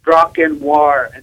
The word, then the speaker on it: Drakkar: It's D-R-A-K-K-A-R, and then noir. So the R is silent at the end of Drakkar.